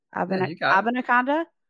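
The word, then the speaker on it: Abanikanda: Israel Bikanda. Do I say that right? – Abakanda. Yeah. – Abakanda. Okay. Sorry, Israel.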